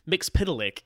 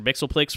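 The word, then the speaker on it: Mxyzptlk: So Mr. Mixpitalik, which I believe is the correct pronunciation. I always called him Mr. Mixoplex forever, but apparently that's wrong.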